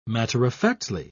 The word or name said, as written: matter-of-factly